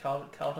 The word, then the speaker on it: Kaldheim: Is that how you say it pronounced? Kaldheim? – I think so, Kaldheim. – That's what I've been saying. Yeah, it sounds right to me.